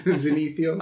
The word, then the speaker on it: Zenithial: That's the correct way to say it. – Like the zenith from the sun. – Yep. – Zenithial.